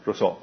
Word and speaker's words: Rousseau: Jacques Rousseau, Rousseau ¿cómo se pronuncia? Rousseau, ah, gracias, Rousseau.